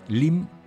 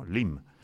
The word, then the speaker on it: Liam: bueno, escrito Liam Clancy, pero parece ser que ellos lo pronuncian de esa forma, ¿no? Lim.